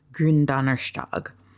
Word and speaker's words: Gründonnerstag: I believe it's pronounced Gründonnerstag, but I don't speak German, so don't take my word for it.